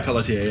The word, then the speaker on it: Pelletier: is that how you say it, Pelletier? – Yeah, Pelletier. Yeah.